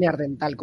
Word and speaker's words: Neandertal: ¿Neandertal o neandertal? ¿Cómo se dice? – Neandertal. – Vale.